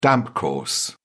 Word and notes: damp course: British English

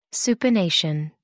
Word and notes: supination: American English

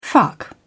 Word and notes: fuck off: British English

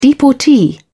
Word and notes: deportee: British English

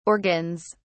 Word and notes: organs: British English

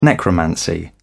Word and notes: necromancy: British English

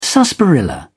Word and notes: sarsaparilla: British English

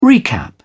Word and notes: recap: British English

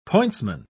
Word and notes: pointsman: British English

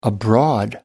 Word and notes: abroad: American English

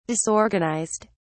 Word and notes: disorganised: British English

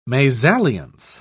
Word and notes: mesalliance: British English

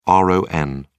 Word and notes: ron: British English

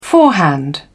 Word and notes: forehand: British English

